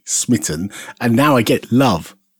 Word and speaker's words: SMIT I18N: So I have called my project SMIT I18N, which is a bit of a mouthful, but it's intended to be pronounced Smitten because it's for the Love game engine.